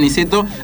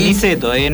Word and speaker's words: Niceto: Niceto, no Niceto, no lo pronuncien mal.